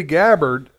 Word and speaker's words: Gabbard: Tulsi Gabbard, is that the way you pronounce her last name? – Yes.